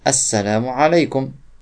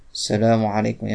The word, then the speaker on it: Assalamu alaikum: Parmi ces conditions, on prononce l'article défini. Assalam, assalamu alaikum. Ainsi, il ne suffit pas de dire salam alaikum. Il y a des gens, ils sont très pressés, ils disent salam alaikum.